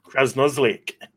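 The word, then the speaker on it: Krasnosielc: But Krasnolik. – Krasnolik. Very good. – I've actually just Googled it, and it's looking like it's Krasnolik. So yeah, we were close.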